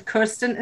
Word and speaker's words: Kerstin: And Kerstin is spelled K-E-R-S-T-I-N and then M-A-R-T-I-N.